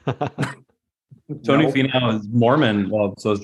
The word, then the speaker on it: Finau: Finau.